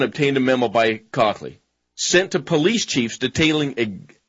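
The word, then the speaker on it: Kochly: In March 17, 2009, presentation before a county board of supervisors over proposed budget cut, District Attorney Robert Cockley, K-O-C-H-L-Y, I believe that would be pronounced Cockley,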